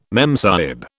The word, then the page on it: memsahib: British English